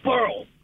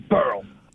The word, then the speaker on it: Beryl: It's B-E-R-Y-L. – Beryl. Beryl. Beryl. – No, Burl. It's a unisex name, but I choose the pronunciation Burl.